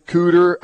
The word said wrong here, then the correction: Kueter: Cuter. K-U-E-T-E-R. Cuter.